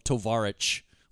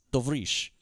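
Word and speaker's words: Tovrish (товарищ): He keeps calling Mikhail Baryshnikov Tovarich, which means comrade. It's Tovrish.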